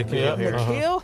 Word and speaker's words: N'Keal: Is it N'Keal? – Yeah. – N'Keal Harry.